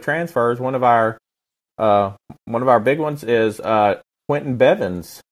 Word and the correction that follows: Bivens: Am I saying his name right? – Yeah, Bivens.